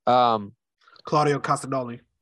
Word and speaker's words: Claudio Castagnoli: Claudio Castagnoli.